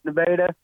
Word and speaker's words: Nevada: It's pronounced Nevada. – Nevada. – Nevada, pronounced Nevada.